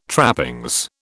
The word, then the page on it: trappings: British English